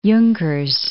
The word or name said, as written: Junkers